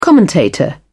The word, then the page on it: commentator: British English